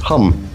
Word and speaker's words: HVMM: Hum is spelled HVMM, and Hum reliably inform us via their website. It's a long story as to why HVMM should be pronounced Hum.